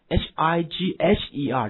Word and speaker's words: higher: 好，什么意思呢？这个单词 hier 这单词啊，higher 这单词啊，其实您读出来就明白，它就来自于什么呢？higher，这个 gh 不发音被省略了，没问题吧？higher，你看高级的人 arch 控制你，是不是等级森严的嘛？